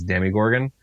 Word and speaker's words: Demogorgon: So I understand that the official pronunciation is Demigorgon, but it just doesn't make sense when it has an O instead of an I or even an E. Where do you get the Demi from? – Yeah, I totally agree with you on that.